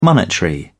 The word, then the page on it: monetary: British English